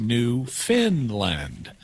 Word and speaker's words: Newfoundland: It's spelled Newfoundland, but it's pronounced Newfinland, even though it's not located in Scandinavia.